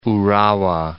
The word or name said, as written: Urawa